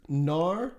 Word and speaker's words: Gnar: His name is Gnar. – G-N-A-R.